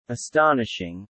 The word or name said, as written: astonishing